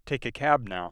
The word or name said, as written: cab